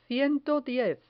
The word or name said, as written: ciento diez